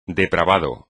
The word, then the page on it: depravado: espagnol